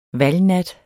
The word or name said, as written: valgnat